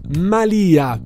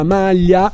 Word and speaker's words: malia: La malia di una strega, non la malia, non la maglia.